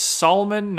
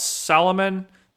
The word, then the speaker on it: Salmon: Salmon has two syllables, not three. It's Solomon, not Salomon.